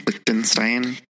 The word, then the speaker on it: Lichtenstein: And this is where we see the Lichtenstein painting. Am I saying it right? – Lichtenstein.